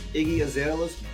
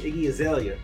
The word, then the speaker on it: Iggy Azalea: Did you get a chance to see um, Iggy Azalea's? Iggy, what's that? How you pronounce her last name? Iggy Azalea, right? Iggy Azalea.